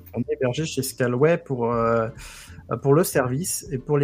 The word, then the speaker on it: Scaleway: Scaleway. Enfin, Anthony, il ne sait pas prononcer, mais c'est Scaleway.